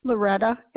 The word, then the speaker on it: Loretta: Loretta, L-O-R-E-T-T-A, Olson, O-L-S-O-N. – All right. I'm sorry, can you spell that last name? – O-L-S-O-N.